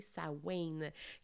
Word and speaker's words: Samhain: Et ce sont donc les origines aussi des fêtes dites de la Samhain, qu'on écrit S-A-M-H-I-N, mais il faut vraiment prononcer Samhain,